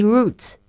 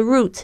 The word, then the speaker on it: route: 搭蓝线观光巴士要多少钱？How many routes do you have？特别注意一下这个 route，也有人会念成 route。